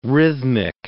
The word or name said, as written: rhythmic